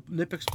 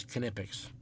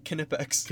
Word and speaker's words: Knipex: And like I said, I don't know if you caught that, but like the older Nipex pliers, and the proper way to say it is Knipex, right? – Yeah, they had T-shirts at SEMA that said it. – Yeah. – It's pronounced Knipex.